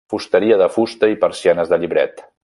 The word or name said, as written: llibret